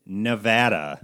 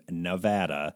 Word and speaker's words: Nevada: It is Nevada. – Nevada. – Is the correct way to pronounce our state's name, Nevada.